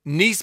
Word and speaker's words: Nice: Well, it's Nice, isn't it? It's not Nice, it's Nice. You don't have Nice biscuits. Well, you do have Nice biscuits, but that's an adjective.